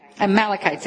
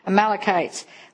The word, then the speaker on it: Amalekites: How do you say that? Sorry. Amalekites. There, I got it. Amalekites.